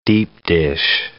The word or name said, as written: deep-dish